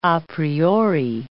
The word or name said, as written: a priori